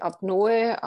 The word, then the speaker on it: Apnoe: Also meines Wissens kommt das Wort aus dem Altgriechischen und es wird Apnoe ausgesprochen. Ich habe schon die verschiedensten Weisen gehört, das auszusprechen. Wollen wir es ein für alle Mal klarstellen? Wie sagt man es richtig? – Also korrekt ist tatsächlich Apnoe, aber zugegebenermaßen sind wir alle ein bisschen faul und sagen Apnoe.